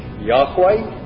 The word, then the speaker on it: Yahweh: The exact pronunciation is lost, but it's thought to be along the lines of Yahweh, or in Latinized, it becomes Jehovah.